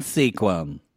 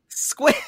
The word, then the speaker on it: Sequim: Welcome to Love in Sequim. – Squim. – Whatever. Well, it's spelled like sequim.